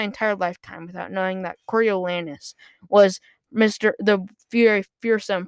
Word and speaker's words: Coriolanus: Coriolanus. At first, the entire time, I was pronouncing it Cornwallis. Then I realized there is no W, and it is ridiculous.